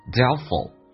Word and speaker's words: doubtful: but if you frown，then look doubtful，那么。 Doubtful 那个 T 是可以不用发音的，